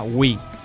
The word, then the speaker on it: Wii: Que dices, Wii, ¿qué es? Pues mira, pues presentamos a Wii, Nintendo. Se pronuncia como la palabra inglesa Wii, que significa nosotros.